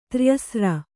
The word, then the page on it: ತ್ರ್ಯಸ್ರ: tryasra